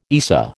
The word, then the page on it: ESA: American English